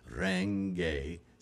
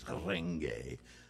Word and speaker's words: Renge: Renge, it's kind of a rolled R there. Renge, it's a lot like Spanish or Latin language. Renge. Not renge, like Americans say it, you know, in the back of your throat. Renge, renge, you know, just roll it a little bit, just tongue.